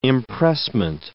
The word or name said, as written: impressment